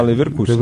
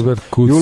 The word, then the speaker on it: Leverkusen: É assim que pronuncia mesmo, é. tá? Leverkusen. Leverkusen.